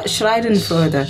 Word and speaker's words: Schadenfreude: Schadenfreude. – Schadenfreude.